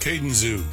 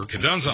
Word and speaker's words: Kadunza: Kadunza. – Kadunza? – Kadunzu. – Kadoozy? – It's Kadunza.